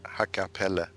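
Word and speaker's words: Hakkaa Päällä: Uh, I would pronounce it Hakapelle. If you speak with someone from, from Finland, they would say Hakapelle, I think.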